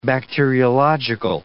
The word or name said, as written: bacteriological